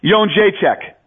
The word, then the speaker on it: Jędrzejczyk: Right, Joanna Jędrzejczyk.